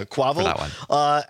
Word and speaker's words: Quavo: And rapper, is it Quavo? Is that how you say it? – I don't know. – Yeah, Quavo.